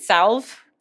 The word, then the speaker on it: salve: Do you pronounce it salve? – Yeah, I would say salve. – Okay, I feel like I've heard people call it salve, but wait, I think they're wrong because there's an L.